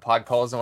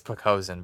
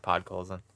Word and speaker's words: Podkolzin: Yeah, it's Pokazin, bro. That's the Don Cherry pronunciation of Podkolzin.